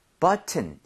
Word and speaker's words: button: 이거 발음할 때 여러분들 네. – 앞쪽에 강세를 엄청 주셔야 돼요. 그래서 여기 강세를 많이 줘서 button 이것도 돼요. 되는데 원어민들이 앞쪽에 강세를 많이 주면서 T를 그냥 거의 없어지게 만들 때가 있어요.